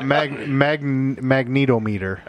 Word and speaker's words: magnetometer: Is it a magnometer or a magnometer? – It's called a UFO finder. – It's actually a magnetometer. – Ah, you were missing a syllable there.